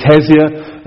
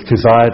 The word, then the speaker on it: Keziah: Some people pronounce it Keziah, but I'm just going to go with Keziah this morning.